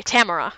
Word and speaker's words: Tamara: Tamara. Her name is Tamara. It's a different stress syllable.